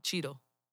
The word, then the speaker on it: Cheadle: Cheadle. – Cheadle? – Mm-hmm. – He's black. He needs to have a better name. Jesus Christ. Don Cheadle. – Let the jokes fly. – Okay. Don Cheadle.